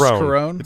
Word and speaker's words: Carone: Melissa Carone, is that how you say it? Or is it Carone? – It's Carone. – It's Carone. – It's just Carone, yeah.